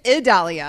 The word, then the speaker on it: Idalia: Yes, Hurricane Idalia. I believe that's how you pronounce it.